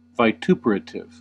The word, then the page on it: vituperative: American English; IPA: /vɪˈtuːpɚətɪv/; IPA: /vaɪˈtuːpɚətɪv/